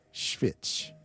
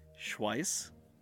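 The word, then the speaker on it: Schwyz: So one of the cantons was called Schwitz, and I'll spell that and then clean off my microphone. But it's S-C-H-W-Y-Z. Schwitz. Schwitz. Schwitz. How would you pronounce that? – Schweiz. – Schweiz. Okay.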